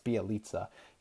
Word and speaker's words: Bjelica: Name uh, Bjelica. That's right, it's Bjelica.